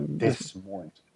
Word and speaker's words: Des Moines: Eh, Iowa, eh, Des Moines. – Des Moines heter ja, det. det. Fullständigt barockt uttal.